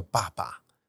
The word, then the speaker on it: Babà: 它的名字如果用意大利文发音，应该叫爸爸。